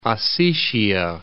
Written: North Ossetia

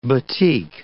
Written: batik

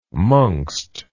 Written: mongst

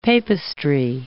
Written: papistry

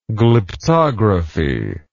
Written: glyptography

